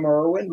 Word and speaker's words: Irwin: So if you've read the book, my email address is William Irwin, my name, spelled with an I, I-R-W-I-N, at kings, K-I-N-G-S dot E-D-U.